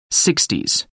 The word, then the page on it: sixties: American English